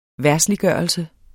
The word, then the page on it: verdsliggørelse: ˈvæɐ̯sliˌgɶˀʌlse; ˈvæɐ̯dsliˌgɶˀʌlse